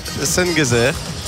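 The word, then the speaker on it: Şengezer: Et alors que sur le banc, on a le numéro 24, Mohamed Sengezer. Ça se prononce comme Ebenezer, Schenezer, ouais.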